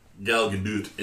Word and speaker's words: Gal Gadot: But it did have Gal Gadot in it. She is, bless you, super hot. That's how you say it. I watched videos.